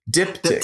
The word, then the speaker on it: diptych: Diptych.